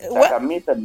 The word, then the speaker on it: Takamita: What is the meaning of Takamite? – Yeah, you're, pronoun- you're pronouncing it very, very correct. Takamita means uh-huh.